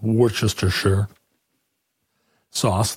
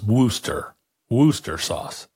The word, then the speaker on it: Worcestershire: I have been told that it is not pronounced Worcestershire sauce. The "shire" isn't even pronounced at all, and ultimately it's Worcester sauce.